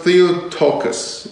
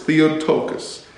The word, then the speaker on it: Theotokos: Do you know the word the, the it's pronounced Theotokos. It's hard for me to say Greek words. Theotokos.